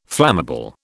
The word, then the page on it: flammable: British English